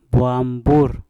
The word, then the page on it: bwam bur: Mpur